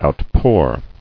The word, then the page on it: Outpour: out·pour